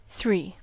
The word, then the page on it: three: American English